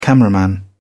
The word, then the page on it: cameraman: British English